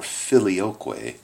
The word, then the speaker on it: filioque: But the phrase, and the Son, or filioque, that's how you say it in Latin, the filioque clause, was added, and the Western churches pray that, and the Orthodox churches reject it.